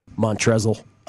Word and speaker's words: Montrezl: The L is silent, by the way. Montrezl. Having himself a game.